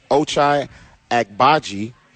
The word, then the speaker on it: Ochai Agbaji: Then you have the rookie wing, Ochai Agbaji.